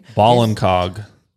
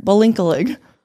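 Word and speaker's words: Ballincollig: No, absolutely not. Balinkog. Ireland, by the way. Balinkalig, Ireland, which if any of you are from Ireland, sorry that I said it right. Mispronounced it. I know I looked it up so many times. I'm really trying here. Balinkalig. It's fun to say.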